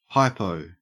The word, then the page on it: hypo: Australian English